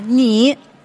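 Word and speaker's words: Ni (你): « Ni ».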